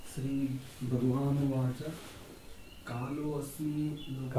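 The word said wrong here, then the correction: कालोऽस्मि: कालो अस्मि ने कालोस्मि कालो जब हम भेद करते हैं तब अस्मि कहते हैं, परंतु श्लोक के उच्चारण के समय वो अवग्रह है ना, ऐसा है ना, तो उसमें कालोस्मि हम उच्चारण करते हैं। केवल शब्द भेद करके समझाते समय हम काल है अस्मि कहेंगे, उच्चारण के समय कालोस्मि।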